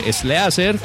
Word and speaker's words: Sleazer: ¿Cómo se escribe Slicer? Es S-L-E-A-Z-E-R. Slicer con Z.